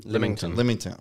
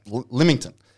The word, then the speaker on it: Lymington: Lymington. – Fuck. Okay. Lymington.